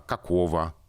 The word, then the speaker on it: какого: Красного, синего, «а, какого. Мы произносим «в», а пишем букву «г» вот эта уникальная ситуация, когда мы, э, ладно, там на конце слов, например, пишем букву «г», хотя произносим звук «к».